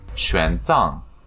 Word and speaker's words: Xuanzang: Xuanzang.